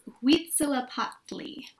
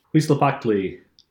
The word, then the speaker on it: Huitzilopochtli: it's Huitzilopochtli. – Huitzilopochtli.